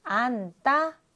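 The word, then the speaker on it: 앉다: 안따, 언따에서 받침, 니은, 지읒은 니은으로 발음합니다. 그래서 안따가 안따 이렇게 발음이 되고요.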